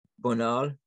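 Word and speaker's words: Bonald: Let's talk about, there's another Frenchman, Louis Bonnet. Is that how you say his name? – Bonal. Yeah.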